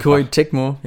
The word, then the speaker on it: Koei Tecmo: Koei Tecmo, ja.